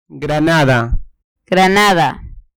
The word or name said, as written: granada